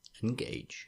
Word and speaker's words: engage: Picard puts more emphasis on the second syllable. Like, engage.